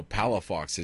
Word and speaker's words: Palafox: Palafox is P as in Paul, A-L-A, F like Frank, O-X.